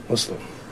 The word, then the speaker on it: Muslim: It's a soft S, like pussy. Muslim.